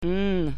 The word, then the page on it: n: latvian